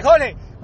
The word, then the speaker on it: Jorge: It's like, Jorge, we don't pronounce the R because it's not because we can't. It's because we're talking too fucking fast that we can just be like, Jorge. The R gets in our way.